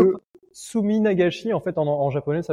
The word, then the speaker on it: Suminagashi: le Susujiban. – Suminagashi.